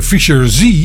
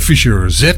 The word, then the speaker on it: Fischer Z: Want die noemde de band dan stug Fischer Z. Maar het is echt gewoon Fischer Z, zo moet je het uitspreken.